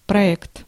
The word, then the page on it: project: Canada US; [pɹɑd.ʒɛkt]